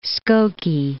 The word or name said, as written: Skokie